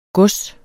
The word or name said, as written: gods